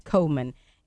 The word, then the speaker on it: Komen: And Comen is a tricky name. It's spelled K-O-M-E-N. – Correct. – Comen.